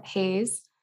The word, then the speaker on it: Hays: My last name is spelled H-A-Y-S. There is no E. So it's Abigail Hayes.